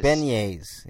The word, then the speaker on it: beignets: Beignets. – Beignets. Yeah, that's how you say it.